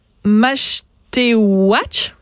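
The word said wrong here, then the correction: Mashteuiatsh: On dit Machouyat.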